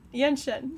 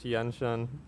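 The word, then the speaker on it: Yeh-Shen: I'm not a pronunciation master. It's just you were like, I'm gonna butcher it. It's just Yen Shen.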